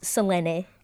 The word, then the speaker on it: Selene: My name is Selene Luna. Selene. – Yes. Luna. – Yes, it reads like Selene, but it's pronounced Selene.